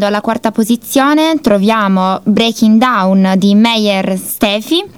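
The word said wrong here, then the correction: Stephenie: Stefani, ti correggo. – Stefani, correggiamo, okay, la pronuncia.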